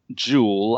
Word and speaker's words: Juul: And his wife, Mona Jewel, I think that's how it's pronounced, Mona, J-U-U-L, played by Jennifer Ely, who is his wife, and she's an official in the foreign ministry.